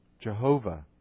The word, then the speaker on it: Jehovah: Jehovah or Yahweh, depending on how you pronounce, which scholar you talk to, how you pronounce that four-letter word of God, that four-letter name of God.